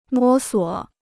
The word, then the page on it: 摸索: mō suo